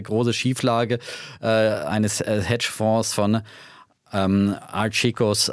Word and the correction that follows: Archegos: wie, wie spricht man ihn nochmal Archigos? Archegos, Archegos. Also die, die Angelsachs haben Archegos, Archegos gesagt.